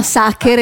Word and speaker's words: Sacher: A parte che si chiama Sacher e non Sack.